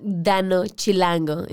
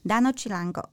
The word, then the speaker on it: Dano Chilango: Dano Chilango. It's like Danish and Chilango is the guy from Mexico. So Dano Chilango. – Dano Chilango.